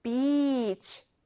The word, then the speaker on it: beach: 이렇게 해변이라는 beach가 나오면 여러분 발음하실 때 e를 길게 늘여서 발음하셔야 됩니다. beach 이렇게 해야 해변이란 뜻이에요. e를 짧게 줄이시면 안 좋은 말이 될수 있어서요. 여러분 해변을 발음할 때 항상 beach 길게 끌어서 발음해 주세요.